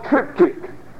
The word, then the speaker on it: triptych: In ancient Rome, there was a writing instrument called a triptych. And if you're taking notes, that's a kind of an odd spelling. It's spelled T-R-I-P-T-Y-C-H.